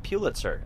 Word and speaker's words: Pulitzer: You have to kind of pause before. – It's not Pulitzer. You got to go pew.